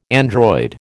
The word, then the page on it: android: American English